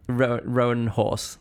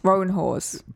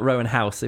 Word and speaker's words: Roanhorse: Roanhorse. – Roanhorse. – Probably not Roanhouse if it's spelt horse, but either horse.